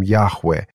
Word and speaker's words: Яхве: Само имя Бога, которое выражалось, записывалось 4 буквами, которые нельзя было произносить, были буквы, которые соответствовали звукам «я-ха-ва-ха». От этого мы получаем «Яхве». Но читали евреи как «Адонай», потому что вот это имя Божие, ну, просто нельзя было произносить.